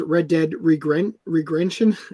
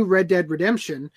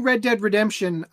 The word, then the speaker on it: Red Dead Redemption: And what happened with the new Red Dead Redemption apparently is that JT Master says Red Dead Regrention, quoting Augie. I'm pretty sure you pronounced it right. – No, he pronounced it wrong. – Anyway, regarding the new Red Dead Redemption, is the new Red Dead Redemption called Red Dead Redemption 2? – Yeah, pretty sure.